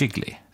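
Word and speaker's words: Gigli: Vi ska prata om allt från giggly, utomlande så, eller är det jiggly? – Jiggly, men det uttalas på lite olika sätt i den här filmen.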